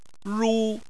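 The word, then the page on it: ru: tone 1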